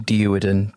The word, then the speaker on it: Duerden: It's actually pronounced Duerden, D-U-E-R-D-E-N.